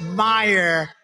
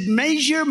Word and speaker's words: Meijer: A guy who spells his name Meijer, but they pronounce it Meyer. The hell kind of a spelling is that? Meijer. It's Meijer, but it's actually pronounced Meyer.